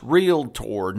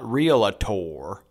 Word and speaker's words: Realtor: It's pronounced Realtor, not Realator.